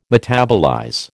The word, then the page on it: metabolize: American English